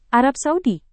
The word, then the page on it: Arab Saudi: indonesian